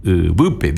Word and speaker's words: Ubupe: U, la B corta con la U otra vez y el P acento en la segunda. Ubupe, viene de suelo, tierra.